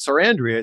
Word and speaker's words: Sarandrea: So, I mean, yours, once you say it, Sarandrea, it's easy.